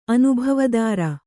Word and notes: ಅನುಭವದಾರ: anubhavadāra

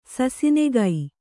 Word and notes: ಸಾಸನಿಗ: kannada; sāsaniga

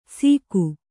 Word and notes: ಸೀಕು: sīku

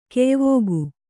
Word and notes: ಕೆಯ್ವುಗು: keyvugu

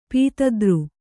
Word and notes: ಪೀತದ್ರು: pītadru

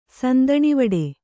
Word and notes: ಸಂದಣಿವಡೆ: kannada

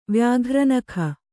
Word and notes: ವ್ಯಾಘ್ರನಖ: vyāghra nakha

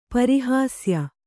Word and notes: ಪರಿಹಾಸ್ಯ: pari hāsya; kannada

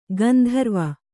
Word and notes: ಗಾಂಧರ್ವ: Kannada

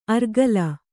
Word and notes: ಅರ್ಗಲ: argala

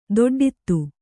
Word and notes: ದೊಡ್ಡಿತೆ: doḍḍite

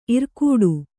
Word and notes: ಇರ್ಕಡೆ: kannada

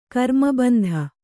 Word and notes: ಘರ್ಮಬಿಂದು: gharma bindu

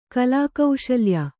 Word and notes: ಕಲಾಕೌಶಲ್ಯ: Kannada

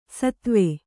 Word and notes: ಷತ್ವ: ṣatva

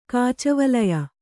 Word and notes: ಕಾಚವಳಯ: Kannada

